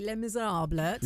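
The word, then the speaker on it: Les Misérables: That's huge to go into how do we say it properly? Les Miserables.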